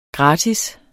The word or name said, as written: gratis